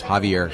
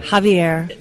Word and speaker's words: Xavier: By the way, the X in my case is pronounced like an H, so it's Javier. – Javier.